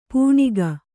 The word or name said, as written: ಪೆಣಕೆ